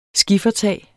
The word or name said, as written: skiffertag